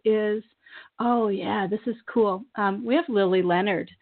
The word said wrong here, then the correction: Leonardi: coming on. She's a Hay House author on March 7th. I'm sorry, Lily Leonardi. Sorry, I said her name wrong.